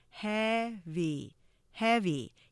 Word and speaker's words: heavy: 그리고 우리가 heavy 똑같이 이음절이지만 액센트가 차이가 있죠. 액센트가 없는 한국어로 말하면 heavy 스타카토가 되지만 이음절의 액센트를 강조하면 heavy heavy 이렇게 강약이 되는 거죠.